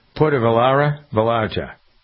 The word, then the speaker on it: Puerto Vallarta: Do I never pronounce that right? It's Puerto Vallarta.